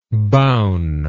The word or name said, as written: boun